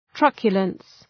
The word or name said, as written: truculence